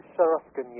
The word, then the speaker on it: Sharashkin: I hope I'm pronouncing that right, am I? – Sharashkin, yes, that's okay. The pronunciation.